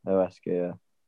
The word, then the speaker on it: Huesca: Is it Huesca? – Yeah, Huesca.